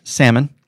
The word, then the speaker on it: salmon: I said it right, salmon.